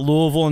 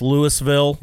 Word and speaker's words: Louisville: It's not Louisville in Tennessee. It's Louisville. – Yeah. – Louisville.